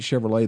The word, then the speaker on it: Chevrolet: You know, you would think Chevrolet, they would spell it L-A-Y, but that's those French for you.